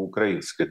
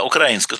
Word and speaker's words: украинской: Постоянно путаю ударение, не обижайтесь, я не специально. А это я говорю некоторым нашим зрителям, которые за этим пристально следят. – Я вам помогу, украинской, да? – Да, украинской, спасибо.